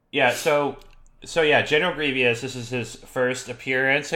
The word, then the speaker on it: Grievous: Except he pronounces his name correctly. He says Grievous.